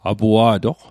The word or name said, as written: apua'toq